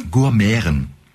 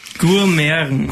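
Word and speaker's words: Gurmiaren: Gourmeren. – Gourmeren. – Also G-U-R, ja, okay. Und dann M, das Schöne, M-I-A-R-E-N, das I-A wird wie Ä ausgesprochen.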